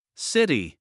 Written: city

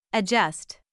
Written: adjust

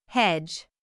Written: hedge

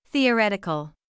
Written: theoretical

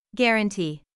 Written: guarantee

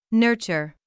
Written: nurture

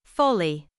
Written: fully

